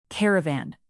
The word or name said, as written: caravan